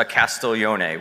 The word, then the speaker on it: Castiglione: So this actually is a Castiglione, which is how you say my name in Italian.